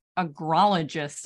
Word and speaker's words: agrologist: And now you're a professional agrologist. Am I saying that correctly? – Yeah, you said it perfectly.